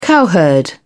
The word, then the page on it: cowherd: British English